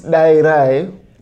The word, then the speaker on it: Dairai: It's pronounced Dairai, but the issue that a lot of people face is because in Zimbabwe, the way in our language, you have D's are pronounced two ways. You pronounce D's as da, but you also pronounce it as da.